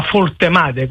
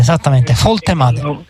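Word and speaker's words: Woltemade: alla Foltemade, così mi dicono che si pronuncia in Germania. – Esattamente, Foltemade.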